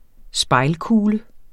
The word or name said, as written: spejlkugle